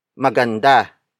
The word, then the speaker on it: maganda: Maganda. Maganda. Maganda is spelled as M-A-G-A-N-D-A.